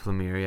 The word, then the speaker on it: Lemire: Is it Lemire? Is that how you pronounce it? Lemire? – Jeff Lemire, yeah. A lot of us think Lemire. Yeah, Lemire. – It was Lemire.